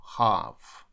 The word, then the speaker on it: half: Буква L здесь снимая, и A читается звуком A, поэтому half. Коробка – box, box, а начальник – bus, bus.